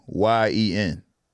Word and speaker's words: Nguyen: But Nguyen was N. It was Nguyen. – Oh, okay. – But you didn't pronounce the damn G.